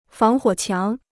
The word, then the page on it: 防火墙: fáng huǒ qiáng